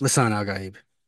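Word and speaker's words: Lisan al-Gaib: How is Lisan Al Gaib pronounced in the movie? Is that how it's pronounced? Is that how it's pronounced? – Lisan Al Gaib.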